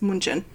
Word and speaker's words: München: So, Germany they pronounce it Deutschland. – Mm. So I don't know how we get to Germany from Deutschland, but the same with we say Munich and there it's München.